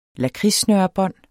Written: lakridssnørebånd

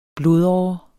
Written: blodåre

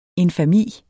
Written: infami

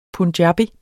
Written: punjabi

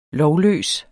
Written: lovløs

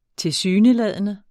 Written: tilsyneladende

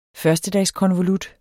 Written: førstedagskonvolut

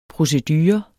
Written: procedure

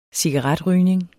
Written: cigaretrygning

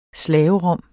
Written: slaverom

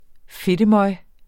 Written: fedtemøg

